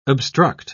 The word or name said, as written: obstruct